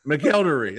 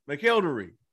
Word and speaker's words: McElderry: I was like, he just told me how to pronounce it. It's McElderry. That's it. McElderry. I was like, yeah, that's what I said.